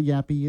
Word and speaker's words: Yaffee: Yes, I'll remind you how to spell it, Y-A-F-F-E-E. It's not Yaffe, it's Yaffe, Y-A-F-F-E-E.